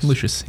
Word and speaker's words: Lucius: Lucius, yeah.